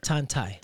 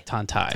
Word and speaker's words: Tantay: Tantay. – Tantay.